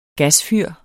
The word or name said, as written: gasfyr